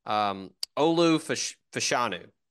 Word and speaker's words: Olu Fashanu: Olu Fashanu.